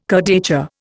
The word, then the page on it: kurdaitcha: Indian